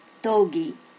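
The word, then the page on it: dogi: native Japanese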